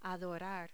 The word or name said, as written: adorar